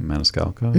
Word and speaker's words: Maniscalco: Maniscalco? Maniscalco? How do you say his name? – Maniscalco.